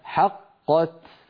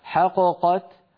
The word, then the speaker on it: حقت: لو قلقلت الأولى لانفك الإدغام، يعني لو قلنا حققت حققت فانفك الإدغام وصار كأننا نطقنا بقافين منفكتين، وهذا لا يصح أبدا، لابد أن نقول حقت.